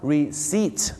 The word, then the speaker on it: receipt: บอลนะฮะศูนย์รถเข็นนะครับเขาจะเรียกว่าทรอลลี่นะฮะไม่ใช่โทรเล่นะครับโทรเล่เนี่ยเป็นนักแสดงตลกมั้งฮะคำต่อไปครับคำที่ลงท้ายด้วยนะครับที่ด้วยตัว BT หรือ PT นี่นะฮะอยากจะฝากบอกนะครับว่าตัว P กับตัว B นะครับหลายๆครั้งไม่ได้ออกเสียงนะฮะอย่างเช่นคำนี้ครับคำว่า receipt ที่แปลว่าใบเสร็จนะครับบ้านเราชอบออกเสียงว่า receipt นะฮะ receipt ไม่ใช่ receipt นะฮะก็จะออกเสียงว่า receipt นะครับผม receipt นั่นเอง